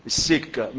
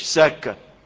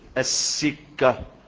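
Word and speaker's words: السِّكة: لا، الحق إنها تنطق بالكسر، فما تتنطقش سكة، لا تتنطق ايه؟ السكة، تشديد والكسر. بيقول وأتجاوز في السكة فغفر له ودخل الجنة.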